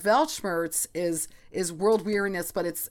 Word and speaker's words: Weltschmerz: Last week we talked about Weltschmerz, written with a W, pronounced with a V.